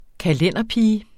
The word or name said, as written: kalenderpige